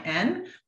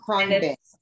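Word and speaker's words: Khruangbin: I went to Krungbin. It's impossible to pronounce or spell. It's Khrungbin, but they're a band in Houston.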